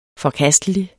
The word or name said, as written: forkastelig